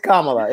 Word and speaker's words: Kamala: Yeah, it's Kamala. It's Kamala. Just think Kamala and add a lie after Kamala.